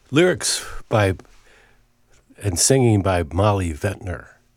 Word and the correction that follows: Ventur: or Ventur is the proper way to pronounce it.